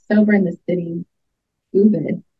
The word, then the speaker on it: Ubud: So I think the official pronunciation is Ubud, but a lot of people call it Ubud, like I call it Ubud. So either or.